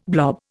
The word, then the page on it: blob: Indian